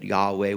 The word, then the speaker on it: Yahweh: The Hebrew scriptures would reveal his name as Yahweh, uh, or if you kind of mess with the uh, vowels there, you'd get Jehovah, which isn't bad. He's been called worse, but Yahweh would be the probably the clearest uh, pronunciation.